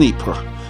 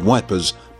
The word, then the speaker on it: Ypres: Searching for metal and timber to shore up trenches, they stumbled upon a printing press in Ypres, known as Wipers by the Tommies, who had difficulty pronouncing the name of this Belgian frontline town.